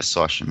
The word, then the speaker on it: Sochi: Alguém sabe como é que fala o nome desse lugar? Acho que é Sochi mesmo.